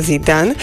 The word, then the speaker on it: Zidane: Číta sa Zidane a tiež sa skloňuje so Zidanom pre Zidana a zapisuje sa bez toho E, pretože ono nemá žiaden vplyv na výslovnosť spoluhlásky.